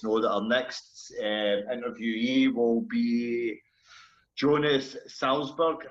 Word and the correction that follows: Salzgeber: if I can Salzgeber, Salzgeber, sorry, I'm not even pronouncing the man's name properly.